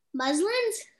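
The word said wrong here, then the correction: Mullens: Mullins. – Mullins. I'm sorry, I can't pronounce it.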